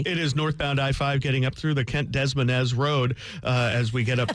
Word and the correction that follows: Des Moines: Is that how you say it? – It's Des Moines. – Des Moines, not Des Moines.